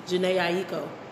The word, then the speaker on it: Jhené Aiko: How did you say her name? – Jen Akeyu. – Okay. I haven't heard it pronounced like that, but okay. – I know who you're talking about now. – But that's not what I heard the first time. And then until you said Big Sean, I've never heard anybody pronounce her name like that. – How did they pronounce her name? – Jhené Aiko.